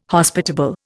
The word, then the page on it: Hospitable: Indian